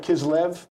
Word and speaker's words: Kislev: Did I pronounce that right? Kislev? – Yes.